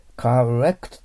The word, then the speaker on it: correct: 次、 correct と発音しますからね。 correct。これを R を L に発音しちゃダメですね。 L にするときはね、カレ、レ、 L ですか。ベロの先を上の歯の裏に当たるようにして、どちらかといえば、かと言った方がいいと思います。